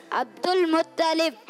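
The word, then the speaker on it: अब्दुल मुत्तलिब (عبد المطلب): हजरत अब्दुल मुत्तलिब, क्या ना क्या प्रोनाउंसिएशन है? – अब्दुल मुत्तलिब।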